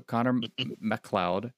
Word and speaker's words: Connor MacLeod: So Connor MacLeod.